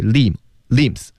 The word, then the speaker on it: limbs: 四肢那个词呢，limbs 那个 b 不发音，就是 limbs，